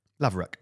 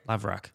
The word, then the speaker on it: Laverack: How do you say his surname? Laverack. – Laverack. – Laverack. Laverack. Ed Laverack.